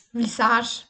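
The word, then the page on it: visasch: Bavarian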